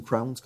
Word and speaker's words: Crohn: C'est une des choses qui explique là, la maladie de Crohn's, ou Crohn's, comme on veut prononcer.